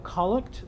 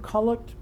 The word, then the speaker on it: collect: Then there's a collect. The word collect comes from the same word that we pronounce collect. It's the noun based on that same verb.